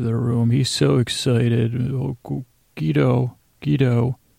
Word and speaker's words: Guido: It's Guido. G-E-E-D-O-H is how it was supposed to be pronounced, I guess, when I was listening.